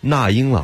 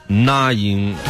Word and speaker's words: 那英: 那么诸如此类的呢，还有那英老师，其实他的名字应该叫那英，以及庾澄庆啊，是不是大家都喜欢叫庾澄庆啊？